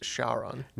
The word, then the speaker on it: Charon: Oh, in that case, it's pronounced Charon. – Right. But, they pronounce it a little different there, but spelled the same way.